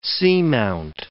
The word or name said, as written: seamount